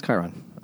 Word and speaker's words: Chiron: It's Chiron. – I wasn't just sure. – Like the thing on the news.